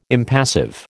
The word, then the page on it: impassive: American English